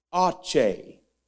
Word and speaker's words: Aceh: And I began trying to find out how to even pronounce it. A-C-E-H. I went to the computer and typed it in, and they began telling me how to say it. Aceh. Aceh. Aceh. You try it.